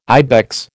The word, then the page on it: ibex: American English